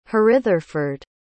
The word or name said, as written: hrytherford